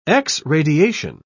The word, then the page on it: x-radiation: British English